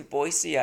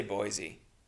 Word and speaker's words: Boise: At a place in Boise, Idaho, which is how they say it, by the way. They don't say Boise, which is really stupid.